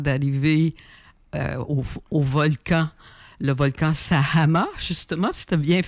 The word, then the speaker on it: Sajama: Alors, j'ai dit, je vais aller grimper le Sajama. C'est comme ça que ça se prononce là-bas.